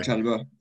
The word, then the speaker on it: Chalobah: Trevor Chalobah, yeah.